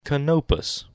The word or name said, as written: Canopus